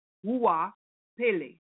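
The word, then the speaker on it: Goapele: Now, it's pronounced, and I was going to say G, but I was told don't pronounce the G. So it's Hua Pele.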